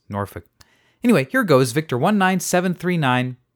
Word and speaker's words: Norfolk: I suppose the idea here is that a lot of people mispronounced Norfolk to be Norfolk.